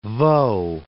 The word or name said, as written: Vaud